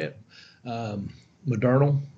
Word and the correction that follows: Moderna: Modernal, Moderna, it's Moderna, Moderna.